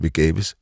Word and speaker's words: Vegaves: Vegaves, endelig latinsk navn, der bare kan udtales. Vegaves.